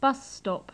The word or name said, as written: bus stop